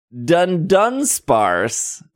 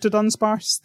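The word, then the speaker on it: Dudunsparce: Everybody's been saying Dundunsparce. There's no first N.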